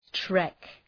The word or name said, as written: trek